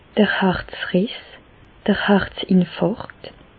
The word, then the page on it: d'r Harzriss, d'r Harzinfàrkt: Alsatian; Bas-Rhin; Reichshoffen